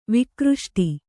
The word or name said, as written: ವಿಕ್ರುಷ್ಟ